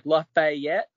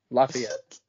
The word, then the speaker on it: Lafayette: There's a lot of people that I've met from other states that are like, Lafayette. Like, it's just say it like you're not smart. Lafayette.